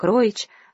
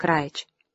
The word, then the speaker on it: Равич: Она только что открыла лавку вееров вместе с одной сатанской студенткой и художником из поляков Равичем, фамилию которого иные произносили как Роич, а сам он как Раич.